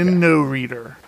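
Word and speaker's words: Inoreader: So are you saying I-N-O, but you seem to be pronouncing a D in there. – Yeah, you're throwing a random D in there. – I-N-O Reader. Okay. Inno Reader. Inno. How would you pronounce that? – Inno Reader. – Inno Reader. – Yeah, you just kept putting reader. A D in there for some reason.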